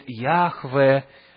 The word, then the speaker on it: Яхве: И некоторые это озвучивают как Иегова, кто-то говорит Яхве, Джехова, по-английски и так далее. И причина тому, что мы не знаем, как точно произносить это имя, заключается в том, что его столько долго не произносили, что люди забыли, какие там нужно гласные вставлять.